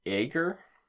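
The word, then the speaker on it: aeger: Ager, which I looked that up and that means to be sick.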